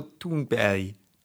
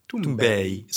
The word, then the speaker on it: Thunberg: I think it's more Thunberg. – Thunberg. Soft G. – It's not like... – But it's closer to berry. It's closer to berry than it is... No, okay. But it's closer to berry than it is to berg.